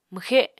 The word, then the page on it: mwxe: Apatani